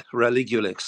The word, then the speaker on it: Relugolix: Am I right in pronouncing it, it's the Relugolex, is that right? Is that how you... – Yeah, Relugolix.